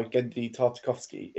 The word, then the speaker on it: Genndy Tartakovsky: And I'm going to pronounce this wrong, but because it's done by Genndy Tartakovsky, that is absolutely correct pronunciation.